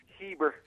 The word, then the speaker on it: Heber: We've got on the line from Haver City, Utah. Did I pronounce that right? Haver? – Heber. – Heber. Excuse me, sir.